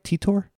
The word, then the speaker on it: Titor: It's not insanity like John Titor. Am I saying that right? Titor? – I'm pronouncing it Titor.